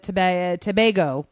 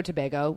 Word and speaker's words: Tobago: Women's, our team played Trinidad, Tobago. – Yes. – I always say that wrong. But Tobago.